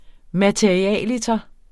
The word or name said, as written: materialiter